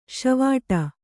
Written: ಷೀವಟ

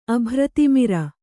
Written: ಅಭ್ರತಿಮಿರ

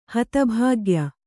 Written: ಹತಭಾಗ್ಯ

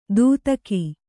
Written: ದೂತಕಿ

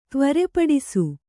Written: ತ್ವರೆಪಡಿಸು

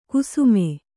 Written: ಕುಸುಮೆ

